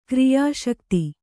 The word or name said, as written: ಕ್ರಿಯಾಸಕ್ತೆ